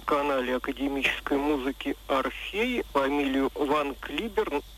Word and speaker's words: Ван Клиберн: в нашей стране ее всегда Ван Клиберн произносили, тем более, что это не английская, а по происхождению голландская фамилия. Да и мы же не говорим Шекспая или доктор Уотсон или Ингланд, мы говорим Шекспир, доктор Ватсон, Англия,